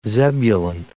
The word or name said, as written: Zebulun